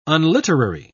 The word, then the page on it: unliterary: British English